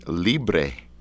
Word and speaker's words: Libre: I want to talk about this CD, Libre. Did I say it right? – You did.